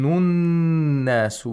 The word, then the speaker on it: النَّاس: We have a sun letter after the alif lam. And nas, noon is a sun letter, so we should not pronounce the lam sakina. And also the hamzatul wasl is not pronounced because it's in the middle of the sentence, of the utterance. يَوْمَ يَكُونُ النَّاسُ. The noon becomes mushaddad and you have to apply ghunna.